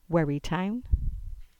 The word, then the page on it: Wherry Town: English